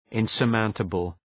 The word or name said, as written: insurmountable